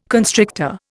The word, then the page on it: constrictor: Indian